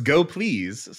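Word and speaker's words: gopls: Now I got to know, how do you pronounce like the language server? – I mean, they told us it's Go Please, so okay.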